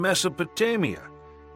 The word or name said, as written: Mesopotamia